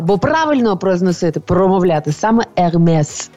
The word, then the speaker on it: Hermès: Ерме це чи Ермес. І завжди в нас так прийнято, знаєте, в суспільстві, що нібито правильно казати ерме, нібито «с» во французькій не, не вимовляється. Але не в цьому випадку. Я була настільки впевнена, що «с» вимовляється, що я посперечалася зі своїм приятелем на браслет шкіряний ермес.